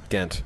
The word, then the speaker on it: Ghent: If we went to, what was it, Gunt? – Ghent. – Ghent.